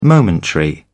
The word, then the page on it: momentary: British English